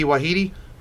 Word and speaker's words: Waititi: Takiti Waititi.